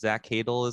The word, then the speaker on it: Zach Cadle: I'm just seeing like the cast list, and Zach Cadle is like the one of the main guys. That's how you say it.